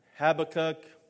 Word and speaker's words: Habakkuk: Habakkuk. It sounds like Abby Cook, though, to me.